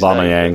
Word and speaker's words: Aubameyang: I think that is it Aubameyang, or was he? I can't never pronounce his Aubameyang.